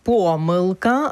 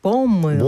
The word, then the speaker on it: помилка: Наше сьогоднішнє слово дня – помилка або ж помилка. – Бонд. – Ні, два Бонд. Наголоси допустимі. Це слово, яке має два допустимі наголоси.